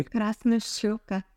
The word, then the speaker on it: Krasnoshchoka: Krasnoshchoka.